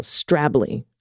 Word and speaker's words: Stradbally: Instead of Stradbally, it's almost one syllable, Strably.